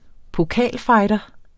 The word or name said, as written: pokalfighter